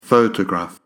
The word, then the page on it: photograph: pho-tograph